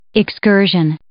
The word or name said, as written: excursion